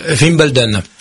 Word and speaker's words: Wimbledon: C'est le début du premier tournoi de Wimbledon.